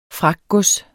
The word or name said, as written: fragtgods